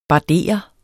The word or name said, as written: bardere